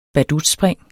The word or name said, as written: badutspring